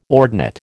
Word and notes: ordinate: American English

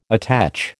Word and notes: attach: American English

